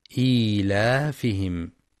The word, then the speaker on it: إيلافهم: وكذلك إيلافهم يخطئ بعضهم فيقرأ إيلافهم فلا ينطق الياء الصغيرة بين الهمزة واللام، والصواب نطقها إيلافهم.